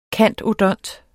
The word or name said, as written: cand.odont.